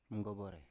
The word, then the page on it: mgovore: Biak